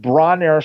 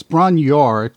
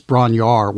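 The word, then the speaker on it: Bron-Yr-Aur: And Bronner Stomp, is that how you pronounce it? – It's Bronyor, because there's two Led Zeppelin tracks. One is Bronyor Stomp and one is Bronyor, and it's Bronyor, which is in the film.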